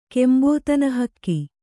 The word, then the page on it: ಕೆಂಬೋತನಹಕ್ಕಿ: kannada